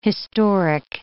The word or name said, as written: historic